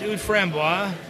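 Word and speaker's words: Oud Framboise: Can you pronounce this for me again? This is the... – Yeah, it's Oud Frambois.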